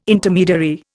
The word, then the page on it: Intermediary: Indian